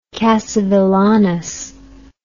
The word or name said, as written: cassivellaunus